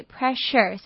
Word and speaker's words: pressure: 那这边要注意 pleasure 跟 pressure 的 sure 呢发音不太一样，愉快 pleasure 的 s 是发 r 的音，那压力 pressure 是发 sh 的音。